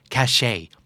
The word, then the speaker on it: cachet: สะกดว่า cachet คาเช็ดเนี่ยนะฮะแต่อ่านว่า cachet